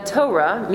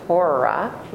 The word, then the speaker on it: Torah: Usually we don't pronounce it Torah. Don't pronounce it that way, because then it marks you as somebody who doesn't know Torah. It's Torah, right? Like a tow truck. Torah, right? So not Torah.